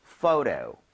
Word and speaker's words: photo: 그리고 참고로 ph는 f와 성질이 비슷하기 때문에 발음할 때 포토가 아니고요. photo. 이렇게 부드럽게 해주셔야 돼요. 한번 가보도록 할게요. photo, photo.